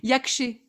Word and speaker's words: Yakch’e: Yakché, ça, ça, s'écrit Y-A-K-C. H et yak, comme un yak et un ché, yakché.